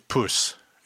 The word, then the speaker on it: poes: The word that you use, the exact word that you would use instead of cunt is puss. It's spelled P-O-E-S, puss.